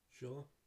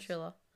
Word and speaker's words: Shula: So the top head coach of the NFL of all is, I'm probably going to butcher his name, but Don Shula. – Yeah. – Don Shula. – Don, yes. Shula. Thank you.